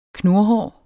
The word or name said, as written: knurhår